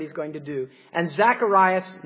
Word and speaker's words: Zacharias: Because when the angel tells Zacharias, or that's the Greek way of pronouncing his name, Zachariah is the Hebrew name way, so I'll probably toggle back and forth, you know.